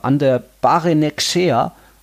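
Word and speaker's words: Ander Barrenetxea: aber über Ander Barenekshea haben wir noch nie Boah, wirklich geredet. Den hast du stark ausgesprochen.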